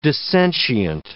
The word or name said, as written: dissentient